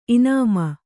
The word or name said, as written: ಇನಾಮ